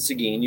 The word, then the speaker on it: Seguin: So I come from what, again, you mentioned Seguin.